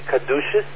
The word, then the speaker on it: caduceus: I wonder, do we say it properly caduceus? That's what we're supposed to say? – Yes.